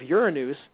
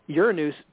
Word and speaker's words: Uranus: Western astrology completely destroyed counterparts because now we give Saturn to Capricorn, and then we give Uranus, that's the way you technically pronounce it, Uranus to Aquarius.